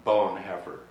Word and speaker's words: Bonhoeffer: People say Bonhoeffer a lot, but our professor of Bonhoeffer at the seminary, whose name was Burtness, always pronounced it Bonhoeffer, and we had kind of got a kick out of that.